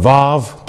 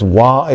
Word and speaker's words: Vav: Today, most Jews will pronounce it with a V sound, that letter in Hebrew. It's called a Vav today, but some people say it's Wah, it's a W sound.